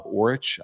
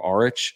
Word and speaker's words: Aurich: And then you've got another coach who's coming over from Idaho, Idaho assistant Rob Orich. I don't know if I'm saying that right, A-U-R-I-C-H or Orich.